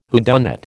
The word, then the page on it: whodunit: American English